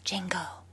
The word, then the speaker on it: Django: What did you guys think of him as Django? The D is silent.